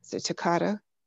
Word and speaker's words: toccata: but to listen to your playing of the toccata.